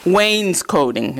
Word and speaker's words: wainscoting: And you want to do wainscoting. Is that how you say it, wainscoting? – That's it. – Yes.